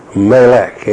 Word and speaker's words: Melech: The word for king is Melech, M-E-L-E-K, pronounced May, M-E-H, Lek, L-E-K. Melech comes from Moloch, M-A-L-A-K, pronounced like Mo, M-A-W, then L-A-K.